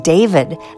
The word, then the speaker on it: D4DV: Go from June gloom smiling to a set I'm lovingly calling my sad sax segment, starting with emerging artist David, that's spelled D4DV, from Houston by way of Queens, New York.